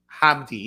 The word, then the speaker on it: Hamdi: In karate, Mr. Tariq Hamdi. I think I'm saying that right. Tariq Hamdi. Yep. H-A-M-D-I.